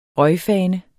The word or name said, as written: røgfane